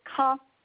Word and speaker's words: Caen: You pronounce it ka, kind of like K-A-H, ka. – Ka? – Oh, ka, okay. – That's just how it's pronounced, versus Caen, which is the one in the south where the film festival is.